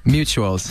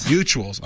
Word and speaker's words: Mutuals: Mutinals? Did I say that right? Mutinals? – Mutuals. – Mutuals.